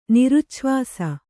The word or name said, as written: ನಿರುಚ್ಛ್ವಾಸ